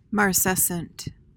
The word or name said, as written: marcescent